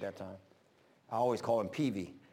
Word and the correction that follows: Prevey: but Prevey.